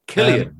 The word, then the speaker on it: Cillian: Cillian, yes.